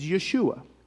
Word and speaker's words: Yeshua: The Hebrew way of pronouncing Jesus is Yeshua, okay?